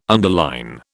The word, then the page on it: underline: British English